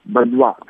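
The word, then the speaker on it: Badoit: Бадуа, ударение на последнем слоге, как все во французском.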